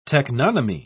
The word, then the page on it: teknonymy: British English